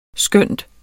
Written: skønt

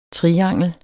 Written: triangel

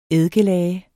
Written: eddikelage

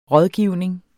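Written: RÅDGIVNING

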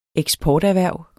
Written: eksporterhverv

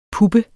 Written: puppe

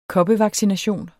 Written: koppevaccination

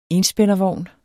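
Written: ENSPÆNDERVOGN

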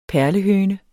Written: perlehøne

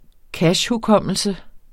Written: cachehukommelse

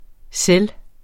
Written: selv

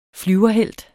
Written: flyverhelt

